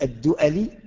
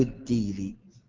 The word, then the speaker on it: الدؤلي: أبو الأسود الدؤلي أو الديلي، من يعرف اسمه؟ أبو الأسود إيش اسمه؟ الديلي أو الدؤلي، تنطق هكذا وكذا، من يعرف اسمه؟